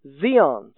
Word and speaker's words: scion: it is spelled in English S-C-I-O-N. A scion is the word in English that means a grafted stock. We could also render this, and we could also pronounce it as Zion, Zion.